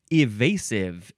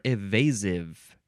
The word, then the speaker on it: evasive: evasive is next, or evasive. The first syllable can be I or E.